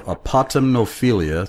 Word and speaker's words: Apotemnophilia: Apotemnophilia, sorry for butchering it before, APOTEMNOPHILIA.